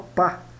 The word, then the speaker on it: pas: Negativo a gente já sabe que sempre vai ter a partícula pa, escrita pas, e se pronuncia pa.